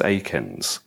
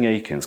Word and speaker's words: Eakins: And I think I responded much more, I'm saying Eakins because I think that's the way that Americans say it. I spent about 10 years just saying Eakins because that's how you say it in Britain.